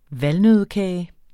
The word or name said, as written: valnøddekage